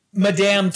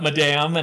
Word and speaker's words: Madame: Madame Tussauds, which we always say Madam, not Madame.